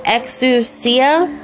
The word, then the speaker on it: exousia: Now, the word powers is Greek word 1849, and it's pronounced exousia, and it means magistrate, superhuman, potentate, token of control, or authority.